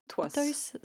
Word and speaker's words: Pithoys: Non, Pitoys. Alors, je ne sais pas comment Merde. Ça se prononce. P-I-T-H-O-Y-S. – Oui, yo, oh, Pitoys. Pitoy. – Pitoys, ça sonne mieux.